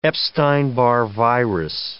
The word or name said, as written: Epstein-Barr virus